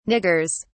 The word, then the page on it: niggers: British English